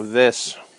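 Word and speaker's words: Wyss: Viss. It's German pronunciation.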